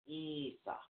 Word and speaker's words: Isa: It's called Isa. – Isa. – Isa. – Okay. – Okay. It's spelled I-S-A. It is pronounced E-E-S-A-W.